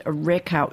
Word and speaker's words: Rakow: And Rakow, am I pronouncing that right? – Yes.